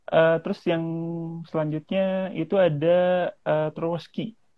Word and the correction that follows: Trochowski: Tro ya bacanya. Trochowski. Trochowski.